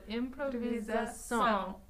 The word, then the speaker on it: improvisação: Every time that I've been like asking you how to say something in Portuguese, I always want to put the emphasis on the wrong syllable. So you taught me, or it's just been become a thing of conducting where to put the downbeat. – It's at the very end. So improvisação.